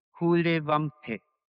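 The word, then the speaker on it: Kuhle Wampe: The name is the name of a suburb of Berlin, Hule Wampe.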